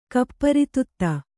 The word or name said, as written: ಕೂಪಾರೋದಿತ